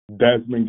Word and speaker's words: Desmond: Desmond Gibbs.